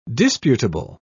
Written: disputable

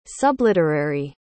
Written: subliterary

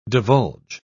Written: divulge

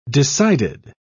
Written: decided

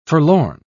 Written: forlorn

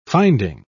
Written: finding

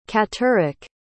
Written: catterik

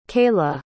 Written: cayla

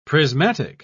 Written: prismatic

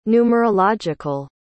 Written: numerological

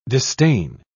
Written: distain

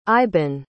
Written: iban

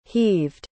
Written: heaved

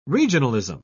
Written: regionalism

